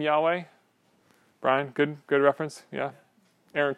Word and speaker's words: Yahweh: We can't pronounce that in our language, right? Like if somebody said, hey, YHWH, pronounce that, you'd be like, so we added the two vowels in it. That's where we get the name Yahweh.